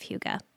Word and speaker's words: Hygge: Well, and just for our listeners' sake, Hygge, by the way, is spelled H-Y-G-G-E, which is not It doesn't roll off the tongue very easily, does it? – No. And I just want to give mad props for pronouncing it correctly three times already.